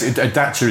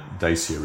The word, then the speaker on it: Dacia: No, so yes, Dacia is the proper pronunciation, but unfortunately by then we'd already gone down the Dacia route.